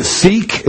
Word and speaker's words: Sikh: The Sikh is what it's called, but it's spelled S-I-K-H.